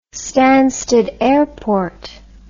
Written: stansted airport

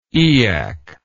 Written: eyak

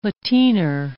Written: lateener

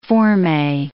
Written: formee

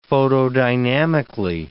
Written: photodynamically

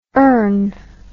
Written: ern

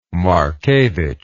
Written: Markevich

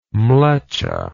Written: mleccha

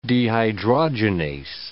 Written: dehydrogenase

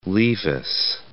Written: Levis

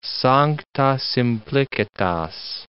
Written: sancta simplicitas

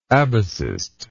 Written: abacist

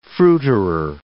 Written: fruiterer

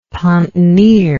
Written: pontonier

